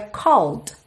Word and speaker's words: called: porque você já sabe que esse é do called não é pronunciado. Logo, você teria called, fechando nesse D, que é consoante oclusiva, pode aparecer mais ou menos.